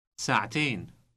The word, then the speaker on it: ساعتين: ساعتين ساعتين. – Note the T sound inserted before the final syllable ain.